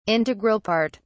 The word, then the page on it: integral part: British English